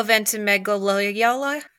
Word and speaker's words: Ventimiglia: Milo Ventimiglia.